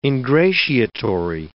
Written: ingratiatory

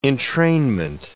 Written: entrainment

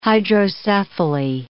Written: hydrocephaly

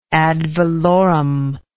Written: ad valorem

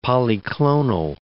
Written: polyclonal

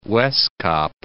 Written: Wes-Kaap

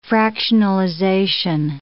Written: fractionalization

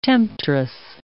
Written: temptress

